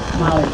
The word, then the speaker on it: Mollie: M-O-L-L-I-E, the initial B, and Sesso is the last name, S-E-S-S-O. – And what does the B stand for? – Bell, Molly Bell. – All right, you want to spell that too? – B-E-L-L-E.